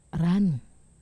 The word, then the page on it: ran(u): wamesa